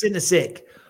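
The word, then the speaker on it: Sinosic: Misspeak Elvis Sionsic's names. Elvis Sinisic. – Sinisic, yeah. There we go.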